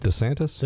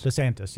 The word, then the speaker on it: DeSantis: DeSantis? – DeSantis, yes.